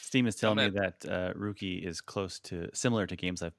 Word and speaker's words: Roki: R-O-K-I. – Okay. But it's pronounced. – I think it's Finnish, so it's like Ruki. – Okay. – I'm sure that's even not even close to the actual pronunciation. Ruki. – That's closer.